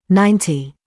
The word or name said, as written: ninety